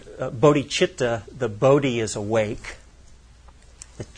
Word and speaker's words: bodhicitta: Bodhicitta, or we pronounce it bodhicitta here,